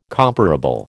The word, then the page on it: comparable: American English